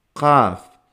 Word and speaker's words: Qaf: Qaf. No es una K de kilo, de cama, de caca, ¿no? Es una Qaf.